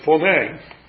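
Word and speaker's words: phoné (φωνή): The word here that's translated sounded, you might be interested in the original Greek, is phoné. Phoné. But we would pronounce that word phone. And that's the way it's spelled.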